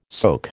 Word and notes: soke: American English

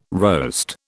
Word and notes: roast: British English

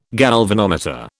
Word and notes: galvanometer: British English

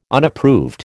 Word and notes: Unapproved: American English

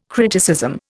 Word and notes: criticism: Indian

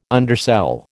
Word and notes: undersell: American English

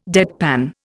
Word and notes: deadpan: Indian

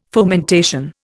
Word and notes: fomentation: Indian

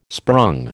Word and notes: sprung: American English

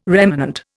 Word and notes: remanent: Indian

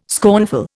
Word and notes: scornful: Indian

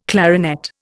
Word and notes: clarinet: Indian

